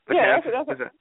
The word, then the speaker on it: Betances: Right now it's probably going to be Betances. – Mm-hmm. – I can never pronounce his name. Betances. – Yeah, you said it right.